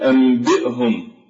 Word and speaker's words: أنبئهم: كذلك في قوله تعالى أنبئهم فكيف ننطق أنبئهم؟ النون لا تنطق وإنما نقلبها إلى ميم فتقول أنبئهم،